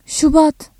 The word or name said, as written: Şubat